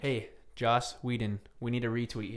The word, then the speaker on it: Joss: Until no, not Josh. – Joss, I said it right. No? – Joss. – Whedon. Whedon. Okay. All right. If you're going to pronounce my friend's name, pronounce it right.